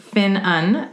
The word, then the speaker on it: Ffynnon: The unusual name Ffynnon, Welsh pronounced Un, means source or spring.